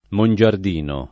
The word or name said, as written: Mongiardino